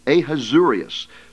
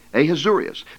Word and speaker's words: Ahasuerus: It's quite a difficult name to pronounce. Ahasuerus. So it begins with an A. Ahasuerus.